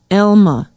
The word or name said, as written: alma